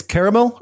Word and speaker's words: Caramel: It's Caramel or Caramel, however you want to pronounce it, depending on what part of the country you're from.